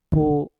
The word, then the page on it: put: mpur